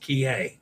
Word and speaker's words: Kihei: It's Kihei. Kihei, it's probably pronounced Kihei. Okay. Yeah, I wasn't sure on that one and didn't have time to look it up.